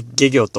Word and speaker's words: 懸魚: でございますが、まあ、その一つにですね、下魚というものがございます。まあ、かかる、上からかけるものですね。かかる魚、かかっている魚といった方がいいですかね。という字を書いて、下魚と読みます。